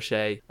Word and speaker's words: Shea: I'm called Joshua Shea. Shea is spelled S-H-E-A.